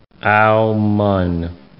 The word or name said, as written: Aomen